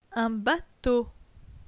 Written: Un bateau

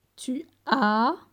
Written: Tu as

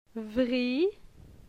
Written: Vri

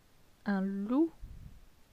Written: Un loup

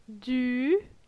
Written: Du

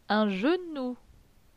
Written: Un genou

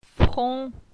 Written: Fron

